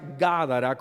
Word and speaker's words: Gádara: Se pronuncia Gádara con acento en la primera A.